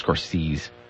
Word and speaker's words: Scorsese: I believe it's pronounced Scorsese.